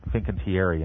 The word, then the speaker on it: Fincantieri: And these are all being built at my personal favorite shipyard, Fincantieri in Italy, which just does beautiful work.